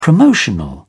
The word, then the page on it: promotional: British English